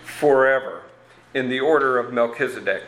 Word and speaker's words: Melchizedek: We were toying with different ways of Melchizedek. No. Well, anyway, I said, you should just say Melchizedek. And pretty soon they'll think, I've been saying it wrong all these times. – It's true. – Yeah, that's more the Hebrew pronunciation of it.